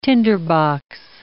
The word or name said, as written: tinderbox